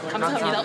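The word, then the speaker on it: Kamsahamnida (감사합니다): Ah, Kamsahamnida.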